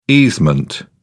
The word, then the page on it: easement: British English